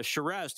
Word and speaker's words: Shiraz: We will also welcome Shiraz to the show. Shiraz, how are you doing? – I'm good. Yourself? – Good. Did I say your name correctly? – That is perfect, just like the wine.